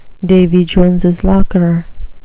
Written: Davy Jones's locker

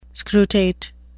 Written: scrutate

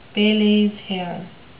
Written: Pele's hair

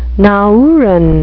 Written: nauruan

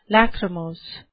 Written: lachrymose

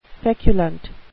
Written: feculent

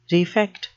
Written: refect

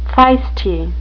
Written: feisty

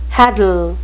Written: hadal

